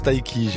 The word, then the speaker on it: Tayc: L'homme s'appelle Taiki. Si jamais vous ne saviez pas, maintenant vous savez. T-A-Y-C. Ça se prononce Taiki.